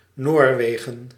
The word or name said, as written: Norway